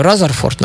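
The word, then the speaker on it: Rutherford: И вот и следующий такой человечек, это Майк э, Разерфорд, наверное, правильно будет говорить, в отличие от Бруфорд, да? Вот, потому что пишется вроде одинаково.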